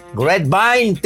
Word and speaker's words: Grapevine: Grapevine, Texas.